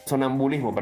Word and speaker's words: sonambulismo: El otro concepto también que es importante que lo tengan está en la parte del sonumbalismo. sonambulismo, perdón, ¿eh? – lo dije mal.